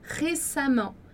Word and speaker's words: récemment: Então, récemment, récemment, récemment com som de A.